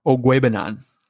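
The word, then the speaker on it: Okwuegbunam: Ogwebanan. – I figured. Is that how you say it? – Yeah. – Ogwebanan.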